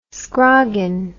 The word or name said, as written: scroggin